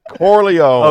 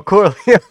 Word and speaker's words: Corleone: I'm Michael Marleone. – Did I butcher that? – Yes. – Corleone. – Oh, Corleone.